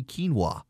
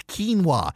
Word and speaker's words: quinoa: He can actually say quinoa. I mean, I look at it as like this looks like it's pronounced quinoa. No, no, it's quinoa.